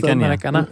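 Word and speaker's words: Maracanã: Maar die Garrincha bijvoorbeeld in Macaraña, of hoe moet je dat uitspreken? – Maracana.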